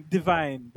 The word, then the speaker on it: Divine: Divine, but it's French, so it's pronounced Divine. Or I think it is.